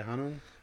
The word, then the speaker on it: Hunnam: Hunnam? Saying it right? Yeah, Hunnam.